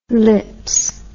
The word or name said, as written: lips